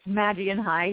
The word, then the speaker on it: Magian High: Am I saying that wrong? – It's Magian High, yeah. – Magian High, yes, of course I'm saying it wrong.